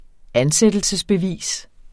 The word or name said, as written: ansættelsesbevis